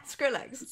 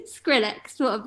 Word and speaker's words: Skrillex: Is it Skillerex? Skillerex? Skillerex? – Skillerex? Wait, what am I? – Skrillex. – Skrillex? Yeah, Skrillex.